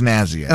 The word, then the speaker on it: snazzy: Is it schnazzy or snazzy? – It's snazzy.